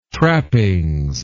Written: trappings

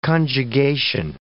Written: conjugation

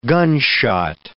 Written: gunshot

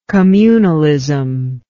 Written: communalistic

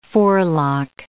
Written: forelock

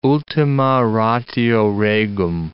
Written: ultima ratio regum

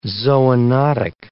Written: zoonotic

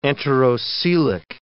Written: enterocoelic

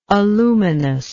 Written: aluminosity

